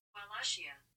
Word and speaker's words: Wallachia: Pronunciation. Wallachia. We were all wrong. Wallachia.